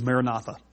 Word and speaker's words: Maranatha: And in the Aramaic, it's Maranatha or Maranatha. The scholars disagree on how it's pronounced. But back in the Jesus movement, It was Maranatha.